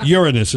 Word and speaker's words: Uranus: Uranus is how it's pronounced.